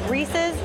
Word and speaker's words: Reese's: so many people say the name wrong of the delicious chocolate peanut butter egg. – It's Reese's Peanut Butter Cups, not Reese's, Reese's.